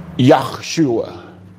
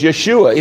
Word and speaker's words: Yeshua: The proper name, way to pronounce it, is Yeshua.